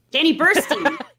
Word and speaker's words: Burstein: Zero Mostel, Chaim Topol, Alfred Molina, Danny Bernstein. Bernstein? I don't know how to pronounce it. – Gwen Stefani. – Danny Burstein.